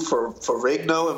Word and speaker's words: Ferrigno: So, but it was like, I think it was Lou Ferrigno.